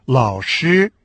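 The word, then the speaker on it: лао-ши (老师): А учитель или преподаватель по-китайски «лао-ши» – третий и первый тон.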